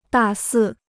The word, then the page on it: 大肆: dà sì